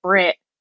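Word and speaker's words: Bret: The other guy's name is Brett, but because they're from New Zealand, they pronounce it Brit.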